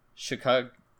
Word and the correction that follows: Sakaguchi: Is it Hironobu? – Hironobu, yeah. – Sakaguchi, yeah. He wrote this.